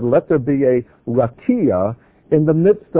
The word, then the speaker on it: rakia: The rakia, that's how it's pronounced.